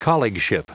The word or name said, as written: colleagueship